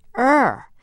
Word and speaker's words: err: Bill Elliott of Billings, Montana asked, how does he say we should pronounce E-R-R? Well, according to Elster, the correct pronunciation is R. But some of us are going to stick with err, because, you know, to err is human.